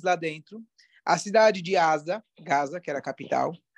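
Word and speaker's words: Asa (עזה): Em hebraico, como se fala Gaza? – Gaza. – Asa. Asa. E Asa aparece aqui no Tanakh.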